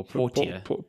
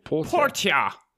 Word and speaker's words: Portia: Is this Porsche as in the car Porsche or? – No. Portia. So I think it's Porsche or Portia. – Portia.